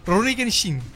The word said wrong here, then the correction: Rurouni Kenshin: Rurouni Kenshin.